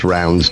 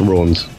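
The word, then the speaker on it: Raunds: No, I mean, I think I've driven past the sign for it quite a lot, and I doubt it is pronounced Rounds, but I might be wrong. I think it's Rawns. – Well, Rawns. They say Rawns. It is spelled with an A, so it's got something different about it.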